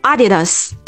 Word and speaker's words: Adidas: 它的重音呢是在第一个音节，所以它读作 Adidas。